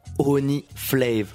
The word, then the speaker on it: Only Flav: Only Flav. Only comme le miel. Okay. Et Flav comme la saveur.